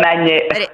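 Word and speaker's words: Añejo: Aneo. L'ho detto bene? – Sì, Ron Aneo. – Ridillo tu, va, Aneo, che qua li chiamano invecchiato, ma noi li chiamiamo Aneo.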